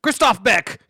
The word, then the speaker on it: Christoph Beck: Score, Christopher Beck. – Christoph Beck. Get his name right.